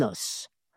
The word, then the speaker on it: os: Donc, c'est pour différencier ces termes à l'oral que la consonne finale S a été restaurée et qu'elle se prononce au singulier dans un os.